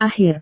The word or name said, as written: akhir